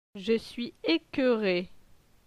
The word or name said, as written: Je suis ecoeure